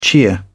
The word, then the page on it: cheer: British English